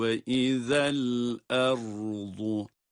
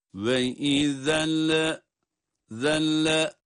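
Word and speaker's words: Ve izel erzu (وَإِذَا الْأَرْضُ): Burada cezimli lamın okunuşunda hareke vermiyoruz. Ve izel. Bakınız. Doğrusu bu. Ve izel erzu. Yoksa şu değil. Bakınız. Bu hatalı olan da söyleyeyim. Hocam ben anladım ama kurtulamıyorum diyeceğiniz bir hata ise bunu çokça tekrar ederek düzeltmeye çalışın. Mesela şöyle okunursa hatalı olur. Dikkat buyurun. Ve izelle zelle. Bakınız lama harike veriliyor.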